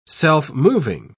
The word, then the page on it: self-moving: British English